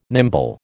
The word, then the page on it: nimble: American English